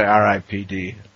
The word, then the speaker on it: R.I.P.D.: Is it Ripped or is it R.I.P.D.? – I would say R.I.P.D.